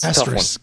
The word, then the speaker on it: asterisk: How do you pronounce that word? – That's asterisk. A tough one.